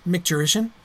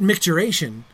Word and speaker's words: micturition: Isn't it the correct term, micturition? But micturation is a common error. It's, micturation is a word in use, but it's a word in use that originates from an error, just like a spelling and pronunciation error of micturition.